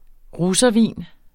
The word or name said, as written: russervin